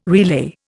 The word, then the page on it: relay: Indian